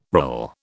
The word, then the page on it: rill: British English